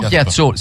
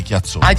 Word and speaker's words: Piazzolla: No, Piazzolla, si scrive Piazzolla. – E perché la chiamate Chiazzolla? – Chiazzolla, diciamo in napoletani, nel gergo nostro chiazzollese, capito?